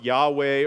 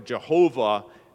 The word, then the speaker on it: Yahweh: This name, we're all pretty familiar with it, is pronounced Yahweh or Jehovah many times today, refers to the covenant God who keeps his promises.